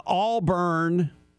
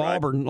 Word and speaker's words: Auburn: Auburn, you know, putting the emphasis on the first syllable instead of Auburn like it's supposed to be.